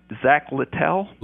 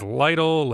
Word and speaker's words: Littell: How do you say the kid's name that's starting for Chattanooga tonight? – Zach Littell. – Littell. Okay. I wasn't sure if it was Lytle, Littell, whatever.